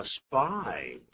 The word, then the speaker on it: spy: A spy 就是间谍，记住那个发音呢 ,the S 后都有 P 的时候啊，变成 spy 啊。